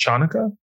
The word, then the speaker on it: Hanukkah: But certainly like when Kate and like when he's saying goodbye after Hanukkah dinner and like. – By the way, I'm just going to stop you. Just so you know, I think it's pronounced Chanukka, if I'm not mistaken.